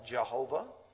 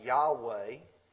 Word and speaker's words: Yahweh: We sometimes call it Jehovah. Now we know a little bit better about the vowel points, and it's pronounced now Yahweh.